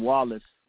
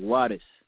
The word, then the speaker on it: Wallace: But at the same time, his father had told him as a young man that Mr. Farad didn't pronounce the name Wallace like that. He said it like Wattis.